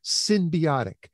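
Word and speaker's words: synbiotic: And that's spelled S-Y-N as in Nancy, not S-Y-M, symbiotic.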